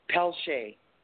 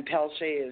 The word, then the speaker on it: Pelletier: Or you can IM me at Shoshona Pelche. And it's S-H-A-S-H-O-N-A. And then Pelche is P-E-L-L-E-T-I-E-R. – I pronounce that different, Pelletier in French.